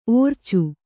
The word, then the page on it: ಒರಚು: kannada; oracu